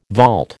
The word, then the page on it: vault: American English